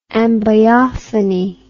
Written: ambiophony

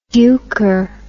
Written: duker

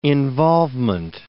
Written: involvement

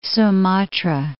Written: Sumatra

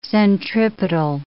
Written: centripetal

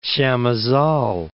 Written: Chamizal

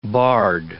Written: barde

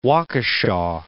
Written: Waukesha

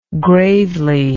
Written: gravely

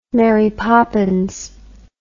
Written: Mary Poppins

